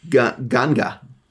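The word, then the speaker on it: nganga: It's N-G-A-N-G-A. I'm going to say ganga. Okay, yeah. That's what I'm going to say. I'm going to pronounce. – N would be silent. – The N is silent.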